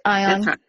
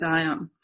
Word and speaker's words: Ion: I'm saying that right. Ion. – That's right. Euripides, Ion.